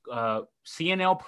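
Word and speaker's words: Cionel: CNL Perez, am I pronouncing that first name? – Yeah, yes.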